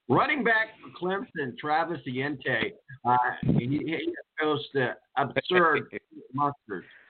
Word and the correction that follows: Etienne: Did I mispronounce his last name? – Yes, Travis Etienne.